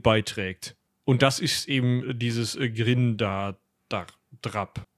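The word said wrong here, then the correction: dráp: Drop. Drop wird das, glaube ich, ausgesprochen, ja.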